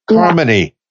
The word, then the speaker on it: Carmoney: It says Carmoney, like harmony. – Oh, yes. Now, I had to do that because people, they'll call me Carmoney or Carmoney, and it's like, no, Carmoney, let it flow.